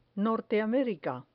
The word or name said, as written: norteamerica